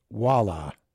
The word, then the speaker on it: Voila: Marty Neon did a song called Voila, pronounced Walla.